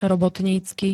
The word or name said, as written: robotnicky